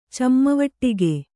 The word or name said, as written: ಚಮ್ಮವಟ್ಟಿಗೆ